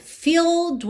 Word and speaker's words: filled: Na primeira, filled with regret. Filled, esse é agora já não é pronunciado. Então do L você vai direto pro D.